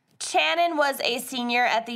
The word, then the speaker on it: Channon: I'm pretty sure it's pronounced Channon. It's spelled C-H. Maybe it's a weird way to spell Shannon.